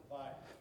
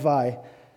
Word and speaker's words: Weil: I love how Simone, how do I say it? Weil. Thank you, I was going to say it wrong. Simone Weil says it.